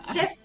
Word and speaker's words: chez: No es Chef, sino es Chef con Z, que eh, viene de, un término francés que en rigor se pronunciaría solamente Che, pero eh, en fin.